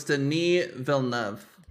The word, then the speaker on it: Villeneuve: I had to look this up. So it's Denis Villeneuve. – Denis Villeneuve. – Denis, not Dennis? – Yeah. – Damn. – Oh my God. – Denis Villeneuve.